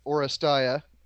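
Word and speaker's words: Oresteia: I've always heard it said Orestia.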